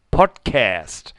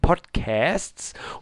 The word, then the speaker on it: Podcast: Und zwar das Wort Podcast wird von dir immer mit einem Ä ausgesprochen. Also Podcast. Und das klingt doch etwas merkwürdig. Höre seit knapp einem Jahr die verschiedensten Podcasts und du bist der Erste, der das Wort anders ausspricht. Meines Wissens nach ist die korrekte Aussprache in Deutsch einfach nur Podcast.